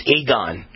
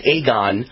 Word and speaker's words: Aegon: In any case, and by the way, someone who is one of my fans named Robert is in the insurance business, and he says the insurance company is pronounced Aegon. How would I know that? I would have had no idea, but it's pronounced Aegon.